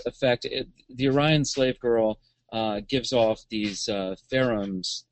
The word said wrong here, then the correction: pheromones: that is that the correct pronunciation? – Pheromones.